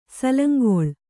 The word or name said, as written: ಸಾಲಂಗುಳಿ